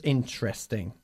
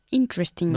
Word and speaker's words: interesting: Okay, how do we pronounce interesting? We pronounce it omitting the first E, okay? So it's interesting. – Interesting. – Not interesting. Okay, interesting.